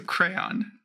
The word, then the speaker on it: crayon: I know you have lots of burning things to say about the, and I'm probably going to say this wrong, apparently, but crown. – Crown. – Okay. It has two syllables, but my mouth just won't do it. – So crayon.